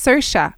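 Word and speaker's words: Saoirse: it's pronounced sersha, like inertia.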